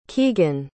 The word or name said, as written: kegan